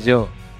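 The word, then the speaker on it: Giò: No se dice Gio, se dice yo. – Yo. – En italiano, Gio es yo.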